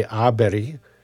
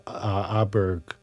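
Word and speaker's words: Åberg: I would say Aberi is the Swedish way to pronounce it, but this is off the Windham Hill piano solos again. Here's Philip Aberg, or Aberi, with Luann.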